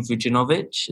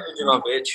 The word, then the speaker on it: Vujinovic: I hope I do not butcher your name, Vujinovic. Is that right? – Yeah, that's correct. – Yes, I got it. So Maja Vujinovic, who's the current CEO of O Group and the former CIO of Emerging Technologies at GE Digital.